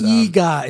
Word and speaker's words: Eegah: EGA. It's spelled E-E-G-A-H.